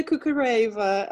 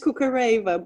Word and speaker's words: Kukhareva: The English version is Kukhareva, but the Russian name would be Kukhareva, which is quite different, but probably unpronounceable.